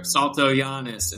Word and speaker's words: Saltojanes: And I want to share, guys, his name is Keith, K-E-I-T-A-H, and his last name is S-A-L-T-O-J-A-N-E-S. So the Jane part that you might want, you're tempted to say Jane, it's Giannis, that's how it's pronounced, but it reads Jane.